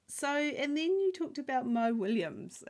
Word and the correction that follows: Willems: and Mo Willem. – Yeah, Willems.